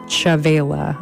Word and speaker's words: Chavela: Then we heard La Llorona from Chavala Vargas. And that was a recording from 1961. I actually think her name is pronounced Chavela. I keep doing that wrong.